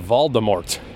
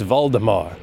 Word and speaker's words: Voldemort: Voldemort. That's so cool. But it was ruined forever because people didn't realize it was French and that they weren't supposed to pronounce the T.